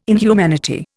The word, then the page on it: inhumanity: Indian